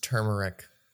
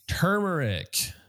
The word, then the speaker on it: turmeric: It's pronounced turmeric. – Turmeric. So it's not turmeric. It's not turmeric. It's turmeric.